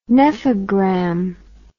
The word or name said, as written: nephogram